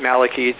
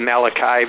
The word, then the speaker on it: Malachy: Now, I always pronounce it Malachy, that as opposed to Malachi, because I don't want to confuse it with the book of the Bible.